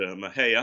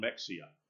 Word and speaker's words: Mexia: Now, when we were heading up to Mahia, and that's one thing Tommy really helped me learn how to pronounce it. That's when I first drove through. I thought, what's that town, Mexia? Okay. But I've been correct, and I think I got it right.